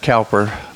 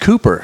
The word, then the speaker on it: Cowper: I used to pronounce it Cowper, but it's really... – Yeah, I got to talk loud. – Okay, it's pronounced Cooper if you're from England, and I didn't know that for many years.